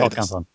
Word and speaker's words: Håkansson: How do you say Håkansson. – This? Okay. – Close enough. – Because the A with the O on top is an O.